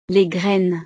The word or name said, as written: les graines